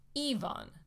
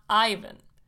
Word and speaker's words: Ivan: is pronounced Ivan, not Ivan.